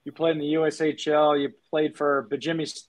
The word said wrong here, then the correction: Bemidji: I'm butcher this. Bemidji State, correct, for 4 years? – Yeah, that's right. Yeah, yeah. Bemidji.